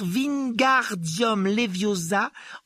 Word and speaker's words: Wingardium Leviosa: « Il faut dire Wingardium Leviosa en accentuant bien le « gar ».